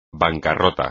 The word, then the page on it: bancarrota: espagnol